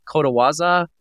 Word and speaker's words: kotowaza: But one of the things I used to love that Al would always tell me these, I think they're called, it's like kotowaza. Is it like a proverb kind of thing? – Uh-huh. – Did I say that right? – Yep.